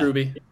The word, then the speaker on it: RWBY: Is that how you pronounce it? – Yeah, it's just Ruby. – Okay. – Yep. – I've always just said R-W-B-Y Ruby.